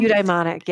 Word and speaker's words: eudaimonic: It's interesting when you were talking about the eudaimonic, am I saying it right? Well-being. – Eudaimonic, yeah.